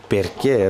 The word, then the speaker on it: perché: Perché, it sounds like porque, like in Spanish.